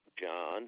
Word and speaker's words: Jon: John, and that's spelled J-O-N, Bolognani, B-O-L-O-G-N-A-N-I.